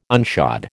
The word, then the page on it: unshod: American English